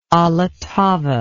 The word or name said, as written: allottava